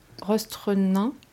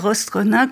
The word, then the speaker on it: Rostrenin: Je ne sais pas si je prononce bien Rostrenin. – Parfait. – Merci. – C'est rare d'arriver à dire Rostrenin comme il le faut.